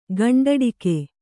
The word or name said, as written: ಗಂಡುಡಿಗೆ